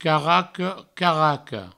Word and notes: caraco: provençal; caraca; caracou

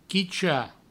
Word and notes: quichar: Provençal; quicha

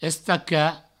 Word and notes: estacar: provençal; estaca

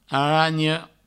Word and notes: aranha: Provençal; aragno

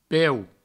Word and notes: peu: Provençal

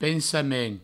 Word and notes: pensament: Provençal; pensamen

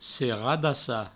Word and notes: seradassar: seradassa

